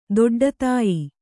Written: ದೊಡ್ಡತಾಯಿ

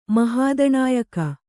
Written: ಮಹಾದಣಾಯಕ

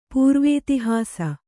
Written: ಪೂರ್ವೇತಿಹಾಸ

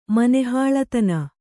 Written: ಮನೆಹಾಳತನ

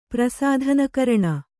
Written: ಪ್ರಸಾಧನಕರಣ

